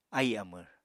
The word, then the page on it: ahì amvr: Apatani